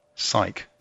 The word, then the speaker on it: Cyc: In the New York Times, there was a piece about Web 3.0 talking about a system called, forgive me, I don't know the pronunciation, but it's CYC. – Psyche.